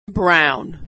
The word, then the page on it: brown: aw